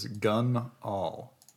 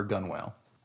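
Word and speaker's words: gunwale: Dad says it is gun all for gun whale. That was my mom. They said that, by the way. So... Yeah, so it's not pronounced gun whale, as I foolishly pronounced it as it would seem to be written, which is always a mistake when it's an English word.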